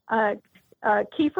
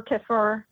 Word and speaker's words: kefir: There's multiple ways to pronounce it, and I never get hung up on it. Some people do. But kefir or kefir, kefir.